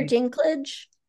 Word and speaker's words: Dinklage: And how do you pronounce it? Is it Linklage? – Peter Dinklage.